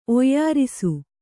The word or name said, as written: ಒಯ್ಯಾರಿಸು